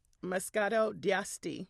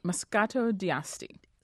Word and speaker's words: Moscato d'Asti: Moscato d'Asti. – Moscato d'Asti.